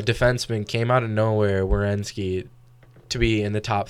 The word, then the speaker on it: Werenski: And how do you say his last name? Zach Werenski.